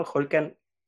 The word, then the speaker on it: Holkan: Pues bueno, Holkan, les comento, es mi nombre y mi único nombre. Tiene origen maya, significa guerrero valiente. Entonces se pronuncia como con J, y se escribe HOLK.